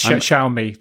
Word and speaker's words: Xiaomi: Is that how you say it, by the way? Xiaomi? – Yes, yes, Xiaomi.